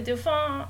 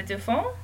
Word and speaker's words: Dauphin: It is not pronounced like Dolphin. – It is not pronounced like Dolphin.